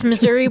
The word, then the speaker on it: Missouri: That's actually how they pronounce it. Misery, I think. Missouri. I thought it was Missouri. Missouri. – Oh, mm. one of the two.